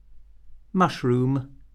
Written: mushroom

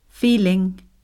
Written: feeling

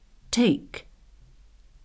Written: take

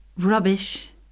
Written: rubbish

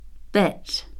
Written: bet

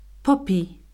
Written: poppy

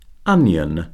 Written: onion

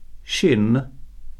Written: shin

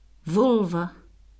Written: vulva

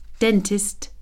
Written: dentist

